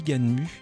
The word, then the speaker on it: Gunm: Enfin, ça s'écrit Gunm et ça se dit Ganmu.